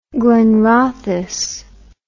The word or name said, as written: glenrothes